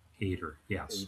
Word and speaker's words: Hader: Hader, yes.